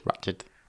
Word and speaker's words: Ratched: Ratchet has come out, or Ratched. How do you say that? Ratched? – Ratched. – Ratched. – I've been saying Ratchet my entire life until that program was released. – I think Ratchet is the Transformer.